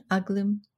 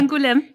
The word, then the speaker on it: Angoulême: Angoulême. Okay. Angoulême.